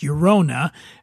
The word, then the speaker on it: Llorona: And that's LLORONA, pronounced Yorona.